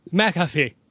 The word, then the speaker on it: McAfee: Brandon McAfee.